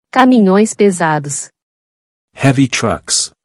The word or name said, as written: Caminhões pesados